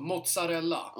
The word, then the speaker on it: mozzarella: ZZ blir TS på italienska. – Ja. – I uttalet. – Ja. – Så mozzarella.